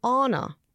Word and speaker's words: Anna: Anna Faris, which I didn't realise until I listened to her podcast, she pronounces her name Anna.